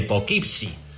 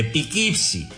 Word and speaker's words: Poughkeepsie: Sobre la pronunciación, que no, tío, se dice, se dice Poughkeepsie. Porque pues no, joder, que se dice Pikipsi.